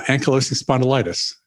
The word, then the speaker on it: Ankylosing spondylitis: And like Juniper, I think was one of your ladies, and she, what is AS? How do you say that? I know that Norman Cousins had it too. – Yeah. Ankylosing spondylitis.